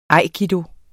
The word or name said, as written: aikido